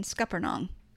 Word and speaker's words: scuppernong: And I feel confident in saying scuppernong. Am I saying it right? – You are saying it right.